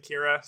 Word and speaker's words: Kyra: Yeah, we, we were scouring some interviews and we, we found out that her husband Kevin Bacon pronounces it Kira, so it's kind of Oh, Kevin pronounces it Kira.